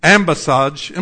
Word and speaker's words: ambassage: Or while the other is yet a great way off, he sendeth an ambassage. I'm not saying that. How do you say that? Somebody. English professors. Where are you? Leanne, how do you say that word? Ambassage. That sounds right. Ambassador, ambassage. Michelle's left, so I don't made her mad.